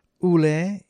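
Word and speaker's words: Ulé: And we began with a tune from Dealer and Los Chicos Altos, Ule, U-L-E, with an accent on the E.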